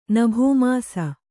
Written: ನಭೋಮಾಸ

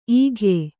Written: ಈಗ್ಯೆ

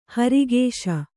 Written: ಹಿರಾಕಸ

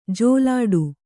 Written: ಜಲ್ಲಡೆ

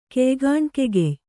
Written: ಕೆಯ್ಗಾಣ್ಕೆಗೆಯ್

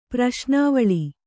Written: ಪ್ರಶ್ನಾವಳಿ

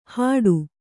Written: ಹಾಡು

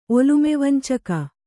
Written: ಒಲುಮೆವಂಚಕ

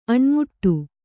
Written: ಅಣಿಮಾಡು

